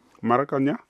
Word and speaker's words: Maracanã: 1-0 durant les prolongations, c'était au Maracanã, c'est comme ça on prononce ? – Le Maracanã. – Maracanã, à Rio.